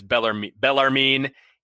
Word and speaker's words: Bellarmine: Because I will tell you why I know it's Bellarmine, because I looked it up on YouTube, and they have a pronunciation YouTube on how to pronounce Bellarmine. And it's Bellarmine, Elon, and Mike Schrage.